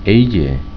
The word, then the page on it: Ei Je: Bengali